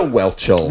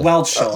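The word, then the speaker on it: Whelchel: Lisa Wischel. – Like a Lisa Welchel. – Welchel, yes.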